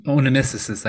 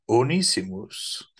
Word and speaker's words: Onesimus: Now the name is pronounced Onesimus.